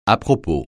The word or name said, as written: à-propos